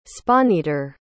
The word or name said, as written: spawneater